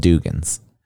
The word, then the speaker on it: Dugan's: Yeah, it's called Dugan's. That's how I pronounced it. There's not a double G. – Okay, what's it really called? – No, it's called Dugan's. It's D-U-G-A-N-S.